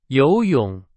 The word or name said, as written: 游泳